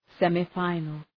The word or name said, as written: semi-final